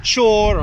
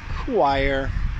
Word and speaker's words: choir: So I've heard chor or chorir or holier. I've seen all kinds of weird things, but a children's singing choir is pronounced choir, choir.